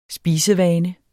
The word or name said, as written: spisevane